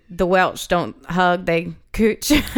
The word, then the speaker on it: cwtch: And cooch, which is the vagina. – CWTCH is how it's spelled.